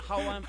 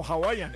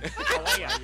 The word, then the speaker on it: Hawaiian: Hawaiian Pizza Day. – No, Hawaiian. Hawaiian. Al principio lo dijo bien.